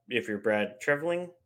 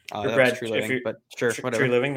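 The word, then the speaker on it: Treliving: But on the other end of the spectrum, though, if you're Brad Treveling, I think that's how you say it. True Living.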